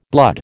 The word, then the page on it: blood: American English